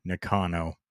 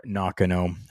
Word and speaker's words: Nakano: taking on Tam Nakano or Nakano, however you would like to pronounce it. There are different ways to pronounce it.